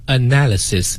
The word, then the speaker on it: analysis: analysis，你看啊，它是 analyze 的一个名词形式，但它读音跟 analyze 不一样，重音也不一样，后面那个 lies 和这里的 analysis 也是不一样的哈。